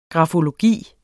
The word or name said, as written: grafologi